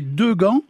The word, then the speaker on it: Degent: On trouve également Degand, D-E-G-A-N-D-T, Degand, D-E-G-A-N-T, et Degand, qui se prononce Degand, mais en fait qui s'écrit D-E-G-E-N-T.